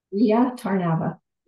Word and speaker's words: Rea Tarnava: I like to get Rhea Tarnava.